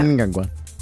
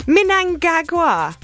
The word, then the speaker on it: Mnangagwa: So imagine you're from Liverpool... All and right. You're talking about your grandma, and her name is Gagwa. Mnangagwa. So that's how I do it.